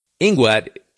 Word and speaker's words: INGUAT: Because I saw the captain's little helper mouth the word Inguat in his ear before we got on board. Inguat is the pronunciation of the first letters in the Department of Tourism, etc., etc., in Guatemala.